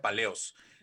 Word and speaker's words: Palaeos: Ah, ok, se escribe Palaeos, pero se pronuncia Paleos.